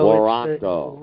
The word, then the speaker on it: warranto: And then Quo Warranto. Did I pronounce that correctly? – Good enough. W-A-R is War Ranto. So Waranto.